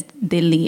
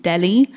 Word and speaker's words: Delhi: It's so interesting because when I was touring India, they, at any car I get in there, and I'd say, or I'd pronounce Dili as Dili and not Delhi,